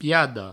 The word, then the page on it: piada: Provençal; piado